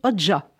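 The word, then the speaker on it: Hoca: En Turquie, il s'appelle Nasretin Oka, prononcé Oja.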